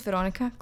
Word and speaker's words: Weronika: It's spelled W-E-R-O-N-I-K-A, and it's like just unique. But you pronounce it the same way as the normal, Veronica.